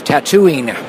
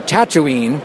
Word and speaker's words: Tatooine: On the other hand, I heard from him later, they also told him Luke's planet is pronounced Tatooine, as opposed to Tatooine. So clearly they didn't even know the original movie stuff.